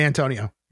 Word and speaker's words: Antonio: Is it Antonio? Did I say that right? – It is. It is the Great Antonio.